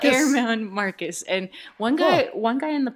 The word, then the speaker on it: Germán Márquez: I think you pronounced that name wrong, though. I've heard on the Rockies radio, it's Germán Márquez.